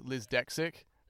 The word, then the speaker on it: Liz Dexic: So the band's name is Liz Dexic, because our singer is quite proudly dyslexic, and it's kind of like the dyslexic pronunciation of the word dyslexic, like. Liz Dexic, and we turned it into a name, like a female's name, like L-I-Z space D-E-X-I-C.